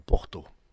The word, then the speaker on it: porta: Quand vous avez un mot qui finit par la lettre A, il se prononce O. Et si vous voyez écrit porta, il faut dire porto.